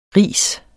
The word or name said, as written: ris